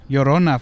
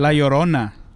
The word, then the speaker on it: Llorona: Llorona, foo. – Llorona. – Okay. La Llorona. – La Llorona, okay. – Llorona.